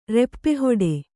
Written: ರೆಪ್ಪೆಹೊಡೆ

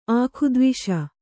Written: ಆಖುದ್ವಿಷ

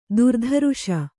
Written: ಥರಥರಿಸು

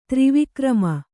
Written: ತ್ರಿವಿಕ್ರಮ

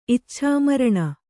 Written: ಇಚ್ಛಾಮರಣಿ

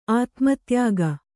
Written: ಆತ್ಮೋದ್ಯೋಗ

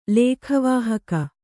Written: ಲೇಖವಾಹಕ

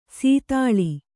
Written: ಸದಳ

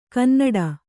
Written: ಕನ್ನುಡಿ

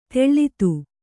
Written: ತಳೆತ

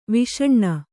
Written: ವಸ್ನ

